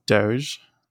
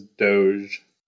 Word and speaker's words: Doge: And so as a meme, I would pronounce it Doge, not doggy. – What about you? – The correct meme pronunciation is Doge.